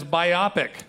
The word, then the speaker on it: biopic: Keegan, would we be better off if we pronounced biopic as biopic? – Yes, we would, because it sounds like myopic.